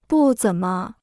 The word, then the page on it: 不怎么: bù zěn me